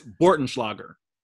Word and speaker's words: Bortenschlager: Believe it or not, guys, it's actually not that hard. You don't look at it, just say it. It's Bortenschlager.